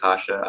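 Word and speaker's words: Kaše: Kasha, pronounced Kasha. I've heard Kasha, Cache. I just know it's not the case.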